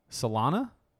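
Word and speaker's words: Salonga: This little number was sang by a one Brad Kane and Leah Salana, I'm assuming is how you pronounce it. Silent G.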